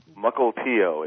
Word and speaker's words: Mukilteo: Mukilteo. It means good campground in some obscure Indian language of a people we decimated a long time ago. – Mukilteo.